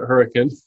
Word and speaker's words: Hurricane: It's actually Hurricane, but we pronounce it Hurricane.